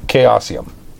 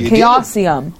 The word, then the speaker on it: Chaosium: Chaosium. That's the company that makes Call of Cthulhu. – I said it right earlier. – Yes, you did. – Chaosium.